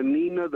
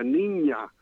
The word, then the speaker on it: Niña: For example, when I was in school, the Nina, the Pinta, the Santa Maria should have been the Niña.